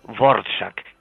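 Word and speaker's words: Dvořák: Vamos a ver, el compositor es ese señor que se suele pronunciar, los que saben un poco de esto, como Dvorak, escrito Dvorak, el de la Sinfonía del Nuevo Mundo.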